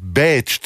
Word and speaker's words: Bécs: Das heißt Bécs, zumindest wird es so ausgesprochen. Das ist wohl die Aussprache ungarischer Art für die Stadt Wien.